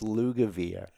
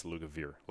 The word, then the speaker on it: Lugavere: But yeah, it's Lugavere, like beer, an alcohol-free version of beer.